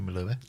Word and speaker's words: Melua: Is Katie Melua or Katie Melua? – Melua.